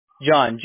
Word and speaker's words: Jon: And just ask for John. Everybody knows me here. They'll transfer you right over. Okay? – Okay, John. You say your name, John? – John. J-O-N. Yes, sir. – Okay, I got the other John. J-O-N. What, CDO? S-O-O?